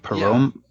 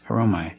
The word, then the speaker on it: poreumai: It's spelled P-O-R-E-U-M-A-I. Pareumai. Pareumai.